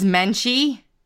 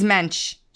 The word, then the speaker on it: Mensch: Yeah, Menchie. – That's how you say her name? – No. – Menchie? – I just say it like that to make fun of her. Louise Mensch.